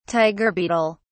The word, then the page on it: tiger beetle: British English